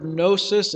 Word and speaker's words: gnosis: Comes from that Greek word gnosis. It has a silent G at the beginning, and it means knowledge.